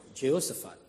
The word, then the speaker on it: Jehoshaphat: declared war to Jehoshaphat. Messenger came and told Jehoshaphat, how do you say that? Jehoshaphat. So I was right.